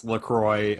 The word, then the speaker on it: LaCroix: And I that was another thing too, is that like people say LaCroix, LaCroix, like there's but it's it's LaCroix according to the company because it rhymes with enjoy.